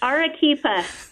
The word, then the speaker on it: Arequipa: It's Arequipa.